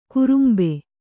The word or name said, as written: ಕುರುಂಬೆ